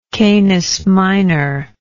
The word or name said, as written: canis minor